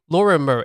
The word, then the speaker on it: Lorimer: Am I saying this correctly? Lorimer. It's like the train station. – Yeah, yeah. You're right.